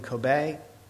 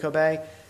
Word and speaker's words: Kobe: Was it Kobe? And am I pronouncing that right? Well, now, what's the real ones? Because I don't want to keep saying Kobe. Not Kobe, but Kobe. So now you know.